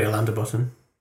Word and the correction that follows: Alain de Botton: I don't know how you pronounce his name. – Alain de Botton.